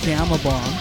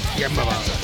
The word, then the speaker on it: Gama Bomb: All right, so the band is Gamma Bomb, although they spell it incorrect. It's spelled G A M A. – Gamma Bomb.